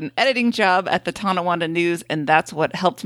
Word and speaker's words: Tonawanda: Andrew did pronounce Tonawanda correctly.